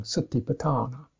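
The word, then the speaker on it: satipaṭṭhāna: But actually the A in sati is short, and the A in patthana is short. The first A is short. Satiput, satiput. But satiput, but the garu syllable lingering on the two T's, retroflex T's, means you get a longer syllable. Satipatthana, satipatthana. So that gives it its particular bounce, you might say.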